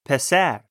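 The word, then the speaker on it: Pesar (پسر): Pesar. – That's right, pesar.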